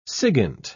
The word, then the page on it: sigint: British English